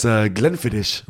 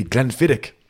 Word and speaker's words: Glenfiddich: He said we pronounce Glenfiddich wrong, and it's actually Glenfiddich.